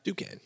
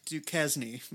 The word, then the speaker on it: Duquesne: Yeah, because I think that just like Duquesne Whistle, is that how you say it? Yeah, Duquesne. Yeah, Duquesne. Um, Duquesne Whistle.